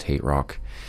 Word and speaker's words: HTRK: That's HTRK, apparently pronounced Hate Rock.